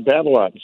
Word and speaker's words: Babolat: The new Babolat. Babolat? – Yeah, yeah. – Pure Drive? – Yeah, you got a Babolat.